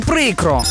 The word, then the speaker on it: прикро: Просто прикро. Наголос завжди на першому складі – прикро. Бо якщо скажеш «прикро», вийде вже не українське слово, а щось нове, новомодне.